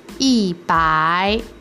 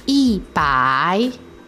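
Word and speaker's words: Yi bai (一百): Yi bai. Yi bai. – Ya, ikuti saya bicara. – I bai, i bai.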